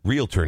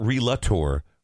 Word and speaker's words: Realtor: His name is Sean Bernard, and he is a realtor for Realtor, not Relator, Realtor.